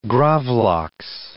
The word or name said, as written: gravlaks